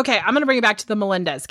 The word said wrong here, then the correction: Menendez: case. – The Menendez? – Yeah, mm-hmm. Did I say it right? – The Bros.